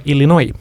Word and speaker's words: Illinois: Ja teine osariikis muide S-häälikud lõpus välja öelda on Illinois, kuid seal taolist eraldi regulatsiooni kunagi vastu võetud ei ole.